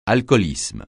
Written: alcoolisme

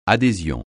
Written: adhésion

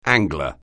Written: angler

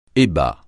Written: ébats

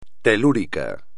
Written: telúrico

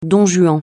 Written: don Juan

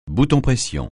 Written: bouton-pression

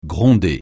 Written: gronder

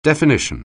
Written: definition

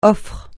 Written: offre

